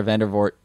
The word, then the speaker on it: Vandervoort: was that apparently Laura Vandervoort, is that how I'm saying that correctly? – Yeah.